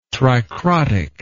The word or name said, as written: tricrotic